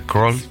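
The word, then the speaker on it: Krall: A propósito, el apellido se pronuncia, eh, se, pronuncia Kroll, pero se escribe K-R-A-L. Diana Kroll.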